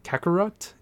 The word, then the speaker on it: Kakarot: So the first game coming out in just a week or so is Dragon Ball Z Kakarot.